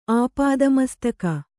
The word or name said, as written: ಆಪಾದಮಸ್ತಕ